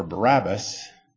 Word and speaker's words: Barabbas: What does Barabbas mean? Or let me pronounce it like the Hebrew would. Bar-Abbas. Son of Abba.